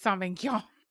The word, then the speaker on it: Sauvignon: Cabernet Sauvignon is how you say it. And um, yeah, Sauvignon. Sauvignon. Yes. Okay.